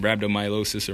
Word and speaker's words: rhabdomyolysis: I believe that's how you pronounce it, rhabdomyolysis or myelosis or whatnot.